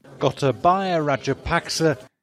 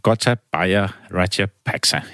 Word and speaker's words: Gotabaya Rajapaksa: klasse, men hun har været inde og øh, hjælper os med at finde ud af, hvordan man udtaler det her navn. – Gotabaya Rajapaksa. – Der kan I bare høre. Gotabaya Rajapaksa hedder han.